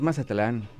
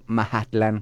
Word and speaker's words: Mazatlán: Pero no es Mazatlán, estás equivocado, se pronuncia Mahatlán.